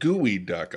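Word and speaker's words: geoduck: Oh, it's not spelled gooey duck. Oh, I get it. – It's spelled geo duck.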